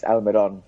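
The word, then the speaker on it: Almirón: It's Almiron, so the sort of stress is on that final syllable. Almiron.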